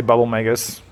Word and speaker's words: Bubble Magus: I have on my tank, I run a Bubble Magoo. Is that how you say it? Magus? – I say Bubble Magus.